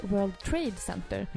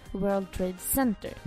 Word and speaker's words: World Trade Center: Det är som när Filip och Fredrik förklarade att det heter World Trade Center och inte World Trade Center. Mm. Betoning på trade.